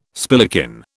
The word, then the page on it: spillikin: British English